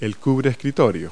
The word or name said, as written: desk mat